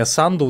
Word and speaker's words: Санду: Конечно же, Майя Санду, ударение на первый слог в ее фамилии.